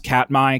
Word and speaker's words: Katmai: I think it's pronounced Katmai, K-A-T-M-I, or M-A-I, excuse me.